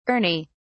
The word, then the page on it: ernie: British English